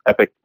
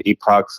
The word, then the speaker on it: Epoch: Yeah, so you can watch Uncle Tom 2 at Epoch TV. Epic. Some people pronounce it Epoch, some Epic, E-P-O-C-H, TV.